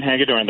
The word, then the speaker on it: Hagedorn: I thank God for Justice Brian Hagedorn. Is that how you say his name? – Yeah, Brian Hagedorn, the one conservative who sided with the three liberals on the court.